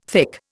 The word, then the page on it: thick: Indian